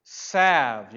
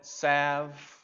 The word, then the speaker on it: salve: Salve. You know that word with the L in it? Salve. The L is silent. Ointment. Salve.